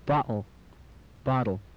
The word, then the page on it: bottle: English